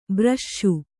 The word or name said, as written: ಬ್ರಷ್ಷು